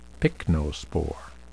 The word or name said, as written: pycnospore